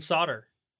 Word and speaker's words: solder: And solder. – Not garage. – Yeah, most people don't well, at least most people in the US don't pronounce the L in solder.